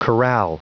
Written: chorale

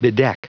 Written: bedeck

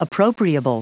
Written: appropriable

